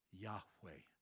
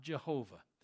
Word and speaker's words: Yahweh: It is best pronounced Yahweh, not Jehovah.